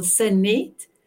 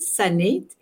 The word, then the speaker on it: Sanet: You pronounce my name beautiful, Sanet. I always say it's sun and it, Sanet. And fun, it's like having fun in the sun.